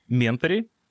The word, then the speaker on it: Ментори: Вот, в частности, программа «Ментори», если я правильно поставил ударение, все правильно, да? – Правильно.